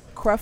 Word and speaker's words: Creflo: Creflo.